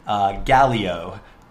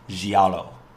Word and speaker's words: giallo: And I, throughout the episode, and probably influenced other people on the show to say it wrong, I said Galeo, which is not right at all. It's not even a thing. The actual name of that genre is pronounced giallo. Giallo. – Wow. – Which is the Italian word for yellow.